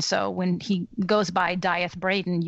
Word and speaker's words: Death: So it's D-E-A-T-H, death. And I looked it up, and it's supposedly pronounced Dyeth, but he makes a joke in the book that most people rhyme it with teeth, but I like to rhyme it with breath, something like that.